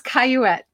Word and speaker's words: Coyette: Well, I had my fingers crossed because with you being Canadian, I was like, it's a name that you might, it's Coyette. – Coyette. I wasn't sure because I think it's French, right? – It is, but it's bastardized the way it's spelled.